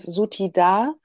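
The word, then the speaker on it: Suthida: übrigens Sutida habe ich in der Fortbildung gelernt, äh, spricht man sie aus.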